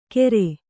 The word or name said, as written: ಗೇರೆ